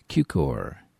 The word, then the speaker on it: Cukor: George Cukor.